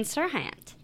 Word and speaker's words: Serhant: I made Molly look up how to say his name because I never know if it's Haunt or Hant. – What is it? – Serhant. – Serhant.